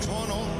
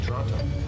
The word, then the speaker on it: Toronto: I want to welcome out of Toronto, within the traditional territory of the Mississauga people. – Where we born? – Toronto. – Canadians don't pronounce the T.